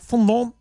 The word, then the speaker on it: fondant: Put the blue fondant as the base of the, am I saying that right? Fondant? – Yeah, that's right.